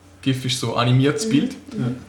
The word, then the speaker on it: GIF: Das heißt eigentlich GIF. – Eigentlich muss man das GIF aussprechen. – Ja, das eigentlich heißt das GIF. GIF. Weil der Erfinder das so gesagt hat auf einem Vortrag.